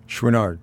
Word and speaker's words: Chouinard: But what's amazing about this story is this family, I guess it's pronounced Chouinard? – Chouinard, right.